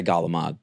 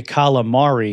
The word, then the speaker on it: calamari: I'd rather somebody say calamari because at least you're sounding out every syllable and it's closer to the way Italians say it than Galamad.